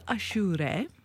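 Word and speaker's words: Aşure: So it is called achure.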